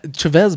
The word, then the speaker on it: Chavez: Why did they say Chavez? Chavez bothers me. – It's Chavez. – Chavez. – Chavez. – Yeah. – America is really how you say it.